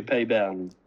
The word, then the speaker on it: Peybernes: I wrote down um, um, Peybernes. Um, have I said that right? How do we say it? French man. – Pei Biernes. – Biernes pronunciation would be Payburn.